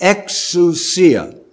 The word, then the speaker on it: exousia: And the word for authority in the New Testament, original language, is the word exousia. I'll spell it for you with English letters. E-X-O-U-S-I-A.